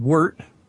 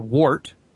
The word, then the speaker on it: wort: There's two different ways of pronouncing it. It's W-O-R-T, and there's some argument over how it's pronounced, but I've been told it depends on where you're from. Some people pronounce it wort and some people wort, but it doesn't matter.